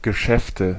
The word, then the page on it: Geschäfte: /ɡə.ˈʃɛftə/